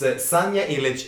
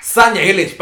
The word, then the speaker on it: Sanja Ilić: We're going to Serbia, and it's Sanja Ilić and Balkanika with the song Nova Deka.